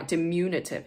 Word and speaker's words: diminutive: I've been mispronouncing this word. Like, I'm mispronouncing it right now. Diminutive. It's not diminutive. It's diminutive. Diminutive is how to pronounce that fucking word. And this whole time, I've been pronouncing it diminutive.